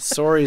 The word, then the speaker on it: sorry: Sorry is not how we Americans pronounce it. You just sounded like you're from north of the border.